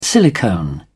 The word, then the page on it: silicone: British English